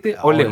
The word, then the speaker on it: Óleo: Entonces está muy buena, también se la recomiendo mucho, Olio. – ¿Cómo se escribe? – O como el aceite, óleo. Óleo.